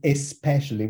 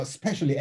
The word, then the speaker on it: especially: So the word is especially, with the E at the beginning of it, but nobody's pronouncing it. So you've got especially, and the way it comes at you, you're not, it's like, am I missing something?